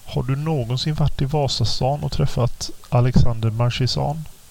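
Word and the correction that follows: Marchesan: Ja, säger man inte markesan? – Säger man så? Jag kan inte, ja, förlåt. Jag kanske förolämpar honom nu. Jag är väldigt dålig på uttal. Och det är markesan. – Bra, du har rätt.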